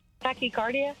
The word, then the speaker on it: tachycardia: Tachycardia.